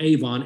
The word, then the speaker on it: avon: It's spelled Avon, A-V-O-N, but it's avon, which means iniquities.